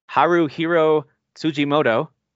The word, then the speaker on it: Haruhiro Tsujimoto: Haruhiro Tsujimoto.